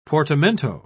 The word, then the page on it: portamento: British English